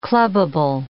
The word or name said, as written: clubbable